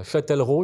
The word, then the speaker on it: Châtellerault: Alors pour info, euh, euh, votre société, hein, Vinyl Records Makers, est située à Châtellerault, j'espère que je le prononce bien. – Oui, c'est bien ça.